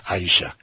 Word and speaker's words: Aisha: En árabe se pronuncia Aisha.